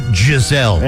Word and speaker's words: Giselle: I believe it's pronounced Giselle. – Yeah, whatever. – Like Giselle's tomatoes in the summertime? – Oh, Giselle. That's her name.